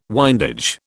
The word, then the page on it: windage: British English